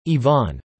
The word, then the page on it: yvonne: American English